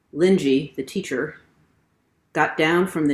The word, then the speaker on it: Linji: So in this story, the teacher or master who is addressing his monks is named Linji. That's how it's pronounced in Chinese.